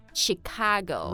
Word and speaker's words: Chicago: Seattle Oh Seattle Seattle 好那最后要说的一个美国城市呢是芝加哥I want to talk about this one because the spelling is C-H-I-C-A-G-O,、mm. 但是它发音不发 Chicago. – Right, right, it's sh.